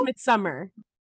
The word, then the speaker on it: Midsommar: Have you seen Midsommar? – It's pronounced Midsummer.